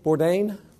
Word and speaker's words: Bourdain: This guy named Anthony Bourdain, is that the right way to say it? Bourdain? Thank you.